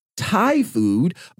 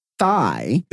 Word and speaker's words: Thai: It talks about, it says, yikes, According to a friend, DeSantis would tell dates that he liked Thai food, but pronounced it thigh.